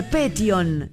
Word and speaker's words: Petión: Y Alejandra, perdón, y Paola, que es de Alejandro Petión. Yo dije Petión, es Petión, acento en la O.